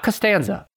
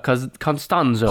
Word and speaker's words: Constanzo: Not Costanza, but Constanzo.